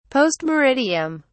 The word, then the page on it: post meridiem: British English